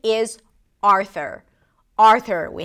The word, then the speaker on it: Arthur: There is no U sound, even though it's spelled with a U, there is no U, Arthur.